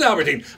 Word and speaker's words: Albertyn: Your last name, is it pronounced Albertyn, Albertine? How do you pronounce your last name? – Albertyn. – It is Albertyn.